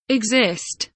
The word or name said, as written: exist